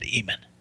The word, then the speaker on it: Amen: who makes reference to the Egyptian god Amen, refers to it by the term Amen, except the Ethiopians. The Ethiopians refer to it as Emen. They pronounce it Emen.